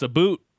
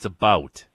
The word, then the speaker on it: about: Oh, uh, it's a boot. No, it's not a boot. it's not a boot. It's about.